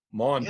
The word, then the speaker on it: Maundy: Maundy.